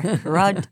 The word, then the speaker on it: Rodinger: R-O-D-I-N-G-E-R. Rodinger. – Rodinger. Yeah, you have to kind of. Father Rodinger, which I'm just going to anglicize his name to Rodinger.